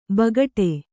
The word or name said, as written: ಬೇಗಡೆ